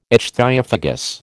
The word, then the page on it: ichthyophagous: American English